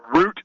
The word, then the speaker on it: Rutte: Yeah, Rutte.